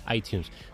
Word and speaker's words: iTunes: iTunes me dicen que se dice así, iTunes.